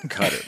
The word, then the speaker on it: Qatar: Does Cutter get upset if people call it Qatar? They feel it's like an effeminate pronunciation. – It's clearly fucking Qatar, but they call it Cutter, man.